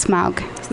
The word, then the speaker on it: Smaug: The Desolation of Smug. – Smug? – I think it's Smaug.